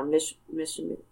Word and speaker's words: Michilimackinac: Michilimackinac.